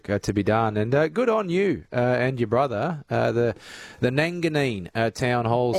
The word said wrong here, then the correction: Nangeenan: So, no, it's not Nanganine, it's Nanjean, and it's like N-A-N-G-E-E-N-A-N.